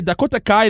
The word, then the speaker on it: Dakota Kai: Dakota Kai, là, depuis qu'elle a fait son heel turn. – C'est le fun que tu prononces mieux les K de même.